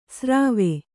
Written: ಸರ್ವೆ

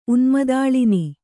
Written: ಉನ್ಮದಾಳಿನಿ

